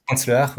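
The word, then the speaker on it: Lehár: Vous parliez de Léar, c'est comme ça qu'on prononce Franz Léar, oui.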